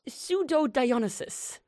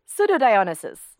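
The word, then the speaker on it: Pseudo-Dionysius: Pseudo-Dionysus. – Pseudo-Dionysus. – Yes. – So it's like Dionysus, but pseudo. – Exactly. – Like a fake Dionysus.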